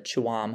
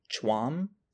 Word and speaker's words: Tuam: It's spelled T U A M, so I thought it was Tuam, but I looked it up, and from all of the videos I could find, it's pronounced like Chwam.